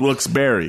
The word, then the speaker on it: Wilkes-Barre: So you could say it's pronounced, it should be pronounced Wilkes-Barre, because that's how that guy's name, the guy's name was Barre. – It's a weird spelling for Barre. – Yeah. – B-A-R-R-E.